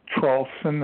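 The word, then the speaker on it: Traulsen: if you Google my last name only, it's Traulson. It's spelled T like Tom, R-A-U-L-S-E-N like Nancy, then put the word and, A-N-B, and then Trump, T-R-U-M-P.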